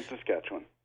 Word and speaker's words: Saskatchewan: Saskatchewan? Sasquatch? What? Okay. I'll just go the other way. That's all I know. – Saskatoon, Saskatchewan.